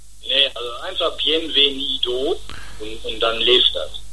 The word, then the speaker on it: Bienvenido: Ich würde jetzt spielen wollen, wenn ich das richtig ausspreche, Bienvenido, oder wie? – Bienvenido.